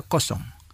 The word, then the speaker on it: kosong: el número 0 a menudo se pronuncia kosong. Kosong, que significa cero.